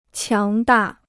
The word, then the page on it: 强大: qiáng dà